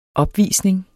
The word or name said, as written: opvisning